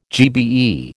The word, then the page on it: GBE: American English